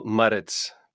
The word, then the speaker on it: Maritz: No, awesome as in, did I say your last name right? I tried to roll that Maritz. I'm not, how do you say it? You know how to say your name. How do you say that, baby? – Yeah, no, I love it. I think you did a good job. Daniel Maritz. But it's good. I love the rolling.